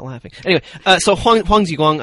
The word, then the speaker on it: Huang Jiguang: So I think, you know, I mean, Huang Jiguang, he was a hero.